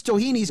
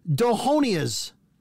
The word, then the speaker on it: Doheny's: It looks because it's spelled H-E-N, Doheny's, right? I know it's Doheny's because I've called and they've gone Doheny's. You know, they say it. – Wow. – But how do you get Dohonia's?